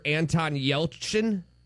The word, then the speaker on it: Yelchin: It's probably the same way actor Anton Yelchin died when he was crushed by his own Jeep.